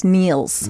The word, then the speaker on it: Nils: N-E-A-L-S? – N-I-L-S. – Oh, Niels. – Niels, but it's pronounced Niels. – Yeah, it's not. – Like Niels Lofgren.